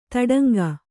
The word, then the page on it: ತೊಡಂಗ: toḍaŋga